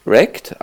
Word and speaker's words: REKT: Wird Wrecked, also zerwrackt, zerstört ausgesprochen, schreibt sich aber R-E-K-T und ist im Grunde genommen eine zeitgemäße Neuauflage des guten alten Stunt Car Racer.